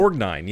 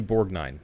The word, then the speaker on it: Borgnine: It's Borgnine, yeah. It's definitely Borgnine.